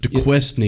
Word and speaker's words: Duquesne: Duqueskine? – Right there. – Which one? – Yep. – Here? – Yep. How do you pronounce that? – Duquesne. Yep.